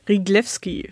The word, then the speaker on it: Ryglewski: Sarah Rüglewski. Herzlich willkommen. – Ganz herzlichen Dank. Und vielleicht darf ich einen Satz zur direkten Kommunikation sagen. Riglewski spricht sich das aus.